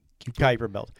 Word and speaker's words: Kuiper Belt: Which could be the entire mass of the Kuiper Belt. How do you say it? – Kuiper Belt.